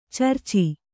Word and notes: ಚರ್ಚಿ: carci

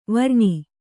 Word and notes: ವಾರಣಿ: kannada

